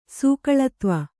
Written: ಸೂಕಳತ್ವ

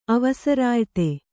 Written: ಅವಸರಾಯ್ತಿ

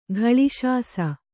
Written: ಘಳಿಶಾಸ

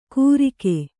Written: ಗುರಿಕೆ

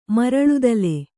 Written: ಮರಳುದಲೆ

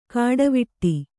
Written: ಕಡೆವೀಡು

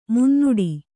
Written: ಮುನ್ನಡೆ

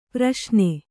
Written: ಪ್ರಶ್ನೆ

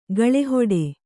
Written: ಗಾಳಿಹಿಡಿ

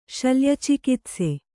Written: ಶಲ್ಯಚಿಕಿತ್ಸೆ

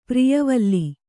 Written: ಪ್ರಿಯವಲ್ಲಿ

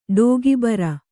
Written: ಟೀಕೆಬರೆ

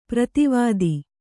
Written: ಪರದೇವತೆ

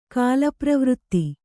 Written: ಕಾಲಪ್ರವೃತ್ತಿ

